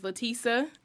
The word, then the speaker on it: Lateasa: My name is Letisa, and that's spelled L-A-T-E. A-S-A, and Spears is the last name, like Britney Spears.